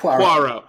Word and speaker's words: Poirot: Poirot, is that correct? – Poirot.